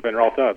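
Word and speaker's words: Van Raalte: So I was pronouncing it right. – Yeah, you actually were Van Ralta, the original Dutch.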